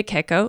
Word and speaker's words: cacao: And then cream de cacao. Cacao? Is that how you say it? Cacao? – Yes. – Right? – Cacao. – Cacao. Like papao.